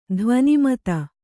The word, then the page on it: ಧ್ವನಿಮತ: dhvani mata